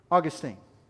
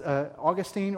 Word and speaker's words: Augustine: Now, Aristotle influenced Augustine. You may know him as Augustine or Augustine. Both pronunciations are actually correct.